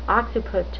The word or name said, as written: occiput